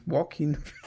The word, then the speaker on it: Joaquin: wundere mich, dass es so ausgesprochen wird, aber es heißt so Joaquin Phoenix.